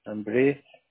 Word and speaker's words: Ambriz: Ambriz. – Ambriz. ¿Se escribe A-M-B-R-I-Z?